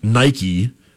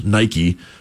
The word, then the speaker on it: Nike: I'm pretty sure it's just, unless there's a company I'm forgetting about, I'm pretty sure at the D1 level, it's just Nike, two syllables, Nike.